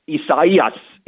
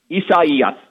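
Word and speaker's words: Isaías: I believe this pronunciation is a Portuguese, because the Spanish pronunciation is a little bit different. But the English name Isaiah is what this name with the letter I is for this particular year. So I believe it's the Portuguese pronunciation. So Isaias is the way I'm pronouncing it, and I hope that's correct, Patrick, but I give you a high score as well.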